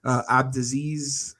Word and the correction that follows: Abdelaziz: Ali Abdelaziz.